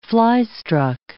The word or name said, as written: fly-struck